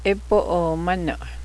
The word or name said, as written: grayish snowy conditions